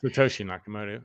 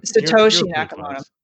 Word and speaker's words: Satoshi Nakamoto: Satoshi Nakamoto. – Satoshi Nakamoto.